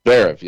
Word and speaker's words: Zverev: How do you say it? Zverev? – Zverev, yeah.